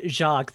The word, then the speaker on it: Jacques: It's Jacques. The S is silent.